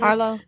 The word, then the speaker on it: Harlow: Okay, what do y'all think about Wendy Hilo? I don't know how to pronounce her name. – Harlow.